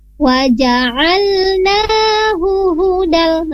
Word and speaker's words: waja'alnahu hudal: Kenapa di sana tidak ada mat ya? Waja'alnahu hudal. Nah, tadi Ibu Rosmiati bacanya waja'alnahu hudal. Nah, tidak bisa dimatkan karena tidak ada mat di sana.